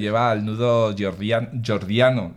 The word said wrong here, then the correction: Gordiano: ¿lo he dicho bien? Jordiano. – Gordiano. Sí, Gordiano, eso.